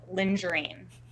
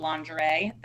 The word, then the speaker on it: lingerie: Let's say he pronounces lingerie as lingerine.